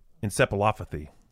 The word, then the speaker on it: encephalopathy: Encephalopathy.